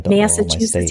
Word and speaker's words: Massachusetts: I think Massachusetts, is that how you say it? Canadian, Massachusetts, yeah.